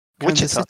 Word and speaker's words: Wichita: Wichita. – Wichita, yeah.